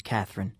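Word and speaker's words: Katherine: It's K-A-T-H-E-R-I-N-E. – Huh. My friend's name is Catherine.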